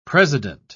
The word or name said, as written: president